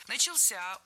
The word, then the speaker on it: начался: Начался — ударение на возвратную часть «ся».